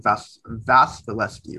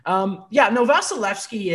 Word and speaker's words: Vasilevskiy: What are your thoughts on that right now with Price and Vasilevskiy, right? – Yeah. – I got the pronunciation down. – Because there's like a KY at the end and it's rough. Never quite know. Vasilevskiy is.